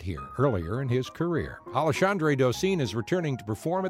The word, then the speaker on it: Alexandre: His first name looks like Alexander, except it ends with R-E. It's pronounced Alexandre.